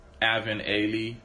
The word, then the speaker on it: Alvin Ailey: You said Alvin and Allie dance troupe. – Something like that. I'm trying to get the name right for the listeners. Alvin Ailey dance troupe.